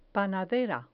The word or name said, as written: panadera